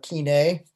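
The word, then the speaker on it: koine: We would call it, using a quasi-modern pronunciation, kine.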